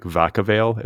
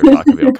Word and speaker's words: Vacaville: There's a park in like Vacaville. Is that the way to pronounce it? – Yeah. I mean, I made it.